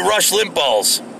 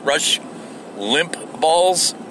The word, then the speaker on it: Limbaugh: But on the way to the job site today, I was listening to Rush Limbaugh's. Did I say that? Yeah, I said his name right. Rush Limbaugh's. Did I say that right? Yeah, I think I did.